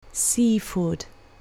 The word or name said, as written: seafood